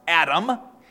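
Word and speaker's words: Adam (אדם): Now that is the Hebrew word pronounced Adam.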